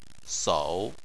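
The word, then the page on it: sou: tone 3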